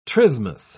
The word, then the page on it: trismus: British English